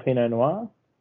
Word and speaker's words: Pinot Noir: And I must say, the pronunciation of Pinot Noir, you've nailed it. Some people, they're a little skeptical of how to approach the name. You've done it well, my friend.